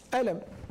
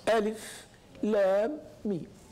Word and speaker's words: الم: ألم. لكن لا يصلح أن تقرأها ألم، تقرأها كما أقرأها النبي صلى الله عليه وسلم ألف لام ميم. فتنطق كل حرف مستقلا.